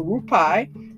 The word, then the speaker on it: Rupi: And the author, let me spell it for you, is R-U-P-I, and last name is K-A-U-R, R-U-P-I, which I believe is Rupi or Rupai, and the last name is K-A-U-R, which I believe is pronounced car.